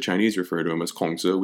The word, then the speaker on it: Kongzi: Uh, Confucius the man, uh, the Chinese word for it, Kongzi, uh, again, that's the 20th century um, Northern Standard Ma- Mandarin Chinese pronunciation of his name.